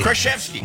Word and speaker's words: Krasinski: Did I pronounce it wrong all the time? – Krasinski. – Krasinski. – Yeah, there you got it.